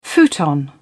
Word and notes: futon: British English

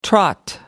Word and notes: trot: American English

